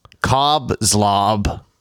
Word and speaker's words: Cobb Zlob: I am your host, Cobb Zlob. – Somehow it's spelled. – C-O-B-B-Z-L-O-B.